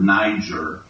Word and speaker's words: Niger: we're told to pronounce that word, Niger, okay, and Lysias of Cyrene and Menenon, which had been brought up with Herod the Tetrarch and Saul.